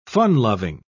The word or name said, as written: fun-loving